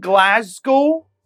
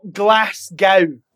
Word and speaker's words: Glasgow: I'm from Glasgow, not Glasgow. All you Americans, you foreign people to me, Lady Gaga came to Glasgow and she said, Glasgow. You all need to pronounce it better. Spell it like this, okay? In your head. G-L-A-Z. G-O. Glasgow. Glasgow. Say it like that.